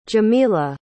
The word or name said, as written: Jemila